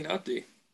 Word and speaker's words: Nnamdi: I'm just going to say Nnamdi.